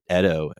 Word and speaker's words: Edo: It takes place in the Edo period, which is I always thought it was pronounced Edo, but they pronounce it Edo in the show.